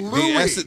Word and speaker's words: Louis: It's Louis. – The S is silent.